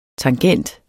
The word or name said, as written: tangent